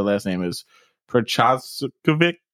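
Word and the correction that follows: Procházka: I think Prochazka.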